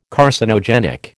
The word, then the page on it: Carcinogenic: American English